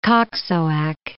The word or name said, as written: Koksoak